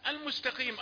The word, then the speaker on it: المستقيم: المستقيم أيضا عرفنا أن أناسا يفخمون القاف أو يعلون درجتها وهي مفخمة في الدرجة الخامسة لأنها مكسورة. قي قي قي المستقيم. وبالتالي التاء حرف مرقق يساعدك على إذا نطقته نطقا صحيحا أن تنطق الذي بعده صحيحا أيضا، فتقول المستقيم، لا تقول المستقيم بتفخيم الحرفين ولا زيادة الخاف أخذت أكثر من درجاتها، ولا تقول المستقي، لا، من لا تأخذ أكثر من حقه.